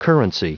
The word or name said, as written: currency